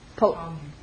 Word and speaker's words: Ptolemy: Ptolemy.